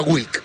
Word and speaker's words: Wlk: O sea, quedamos en Wick. – Se pronuncia Wick, exactamente.